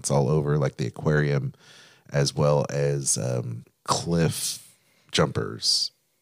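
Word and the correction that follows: Claim Jumper: Did I say that right? – Claim Jumpers.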